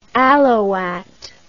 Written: alouatte

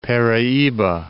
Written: Paraiba

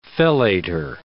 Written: fellator

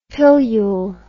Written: pillule

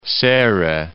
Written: Serrai

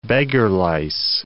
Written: beggar-lice

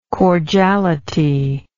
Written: cordiality

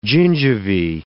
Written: gingivae

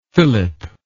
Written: Phillip

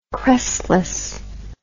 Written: crestless

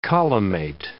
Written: collimate

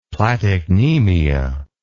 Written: platycnemia

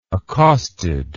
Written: accosted

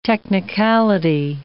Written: technicality

